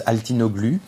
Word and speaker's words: Altinoglu: And of course, I'm French, so it's pronounced now in the French way, which is Altinoglu. But my mother tongue, even if I was born in France, is Armenian.